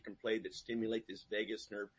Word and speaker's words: vagus: Is it vagus? I hate to interrupt. Is it called vagus nerve? How do you spell it? – V-A-G-U-S. – V-A-G-U-S. – Okay. Vagus. – Okay. – It's like Las Vegas.